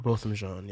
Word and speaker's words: Botham Jean: Uh, his name was Botham Jean, or Thank I think you. Botham John. – I, I think it's John. – Uh, Botham John, yeah.